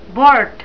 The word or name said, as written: bort